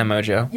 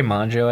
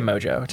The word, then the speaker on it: Mongeau: You can say Mongeau, I guess. I've heard that a lot. Mongeau? But, like, yeah. How but I say Mongeau. Tana Mongeau.